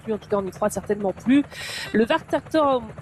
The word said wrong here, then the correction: Wartburgturm: alors non, je recommence, normalement je suis pas mauvaise en plus hein, en prononciation allemande, le Wartburgturm, voilà, ça y est,